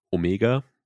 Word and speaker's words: Omega: Und ähm, Omega hat ein bisschen Angst davor. Omega, nachdrücklich richtig ausgesprochen,